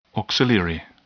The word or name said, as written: auxiliary